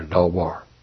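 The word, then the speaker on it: Dawar (דבר): D-A-B-A-R, pronounced like Daw, D-A-W, and then B-A-W-R, Dawar.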